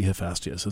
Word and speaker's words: Hephaestus: Hephaestus, it must be Hephaestus is the way to pronounce that.